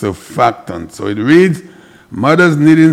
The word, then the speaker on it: surfactant: All right, the name of the word is pronounced surfactant. S-U-R-F-A-C-T-A-N-T, surfactant.